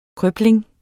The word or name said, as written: krøbling